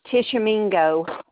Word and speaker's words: Tishomingo: Tishomingo.